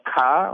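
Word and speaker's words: Kåre: From what I understand, by the way, it's pronounced Ka, like a Boston accent when you say car.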